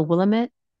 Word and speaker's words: Willamette: She lives in, I think it's the Willamette. Am I saying that right? Valley? – That sounds right.